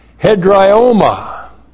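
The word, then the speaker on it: hedrioma (ἑδραίωμα): In our verse we have the adjective hedraios, but there's also a noun, hedrioma. H-E-D-R-A-I long O-M-A. Hedrioma.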